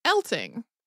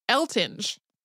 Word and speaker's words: Eltinge: There are numerous print sources from when he lived that insist that it was pronounced Elting, like with a hard G. But there is a lot of old footage floating around, as well as more recent footage of people talking about him where people say it Eltinge.